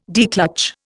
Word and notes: declutch: Indian

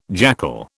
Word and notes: jackal: British English

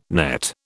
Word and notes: nett: British English

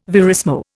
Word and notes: verismo: Indian

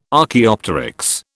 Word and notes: Archaeopteryx: British English

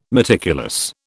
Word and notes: meticulous: British English

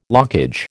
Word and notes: lockage: American English

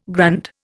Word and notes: grand: Indian

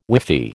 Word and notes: whiffy: American English

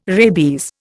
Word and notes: rabies: Indian

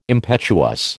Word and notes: impetuous: American English

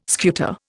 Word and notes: scuta: Indian

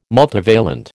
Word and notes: multivalent: American English

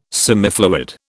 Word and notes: semifluid: British English

